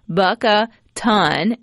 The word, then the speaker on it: Bucatana: And so Janet texted in, "It's pronounced bucka ton," and then she put 2,000 pounds, like like a ton, Rebecca, like. or like an elephant.